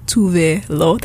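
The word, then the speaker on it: Tove Lo: So, what you just heard was Habits Stay High by Tuve Lo.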